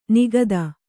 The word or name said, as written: ನಿಖಾತ